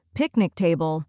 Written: picnic table

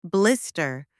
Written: blister

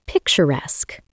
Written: picturesque

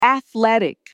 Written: athletic

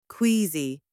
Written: queasy

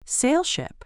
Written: sail ship

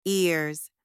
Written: ears

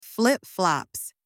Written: flip-flops

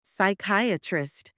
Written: psychiatrist

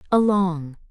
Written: along

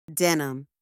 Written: denim

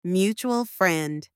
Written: mutual friend

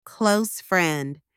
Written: close friend

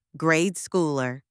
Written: grade schooler

